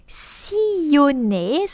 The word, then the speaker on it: sillonner: The verb sillonner, so with a double L after the I, so it's pronounced I, as in fille or famille.